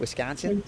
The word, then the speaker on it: Wisconsin: Wisconsin? – You did.